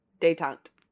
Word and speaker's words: détente: Reagan dismissed dante. – Détente. – It's because you say it and then I look at the word and right. I can't. It doesn't look how it's spelled because it's French.